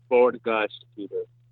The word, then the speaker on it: Bord Gáis: Bord Gáis Theater. – Bord Gáis. – Can you say it on the phone? – Say it right here. – Bord Gáis Theater.